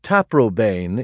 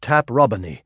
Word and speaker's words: Taprobane: The name Taprobane is now usually spoken to rhyme with plain, but the correct classical pronunciation is Taprobany, as Milton, of course, in Paradise Regained, Book 4, well knew.